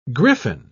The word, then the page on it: gryphon: British English